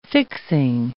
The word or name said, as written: fixing